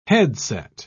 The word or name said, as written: headset